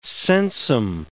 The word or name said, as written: sensum